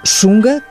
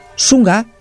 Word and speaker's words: Shunga: A arte Shunga, com SH, Shunga, assim é que se pronuncia, a arte japonesa, milenar, ousada, explícita.